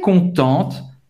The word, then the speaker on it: contente: Je suis très contente. Eu, eu estou muito feliz. Hein? Ela está muito feliz. Eu estou muito feliz. Para uma menina é contente. Você vai pronunciar o T no final.